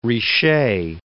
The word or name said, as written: Richet